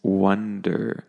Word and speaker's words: wonder: And the next word, I mentioned this one because they sound very similar, but be careful with the vowel sound. So the first one is wander, and the second one is wonder. So one is sort of wan, on, onder, and the other is wonder. Sort of like how you say the number one, I wonder. It's kind of similar to that pronunciation.